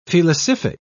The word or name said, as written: felicific